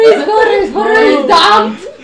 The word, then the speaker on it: Damned: Vimos Village of the Damned. No, pero no se dice Damned, se dice Damned.